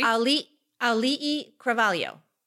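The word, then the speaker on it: Auli'i Cravalho: Ali'i Cravalho. – See? Like, it's a fun name to say. – Ali'i. It's so good. – Ali'i Cravalho.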